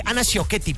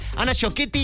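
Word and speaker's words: Ana Ciocchetti: Aparecen por allí en escena Ana Coschetti, Isabela Camil, Ana Sciocchetti, perdónenme, lo dije mal. Ana Sciocchetti, Isabela Camil y Sabine Mussier.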